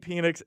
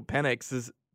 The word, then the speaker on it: Penix: They must have just had Desmond Howard telling everyone he's going to bring that big penis energy, which is not how you pronounce Michael Penix's name, Michael Penix Jr.'s name.